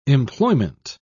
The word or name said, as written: employment